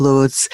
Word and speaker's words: Luz: I've been corrected over the course of the break, and it's on me. I'm sorry, I didn't realize the correct pronunciation of Loose.